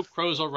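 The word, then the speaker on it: Krozel: Thursday, December 30th, by Joe Crozel, rhymes with puzzle.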